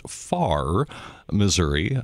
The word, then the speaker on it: Farrar: because it looks like it's Farrar, Missouri, but I'm guessing it's pronounced Far, Missouri, of your churches, Salem Lutheran Church and then Zion Lutheran Church in Crosstown, Missouri. – Yeah, and you're right. It is pronounced Far, as in not close, but Far. – As in not close, that's right. – And in my family, we have kind of a joke, which AR is silent.